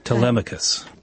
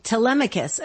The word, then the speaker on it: Telemachus: But here's the Telemachus. – Telemachus.